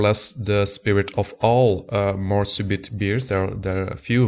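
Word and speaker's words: Mort Subite: It's very difficult to pronounce that in English when you're not really used to it. In French, it would be Morsubit.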